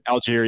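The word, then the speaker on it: Allgeier: And that's big for both these teams, you know, Abram Smith, and it's pronounced Algier, correct? Algier? Is that Algier, yeah, Tyler Algier.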